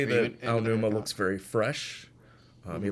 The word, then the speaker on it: Onuma: Onuma.